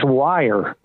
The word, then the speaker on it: Weir: Pronounced Wire.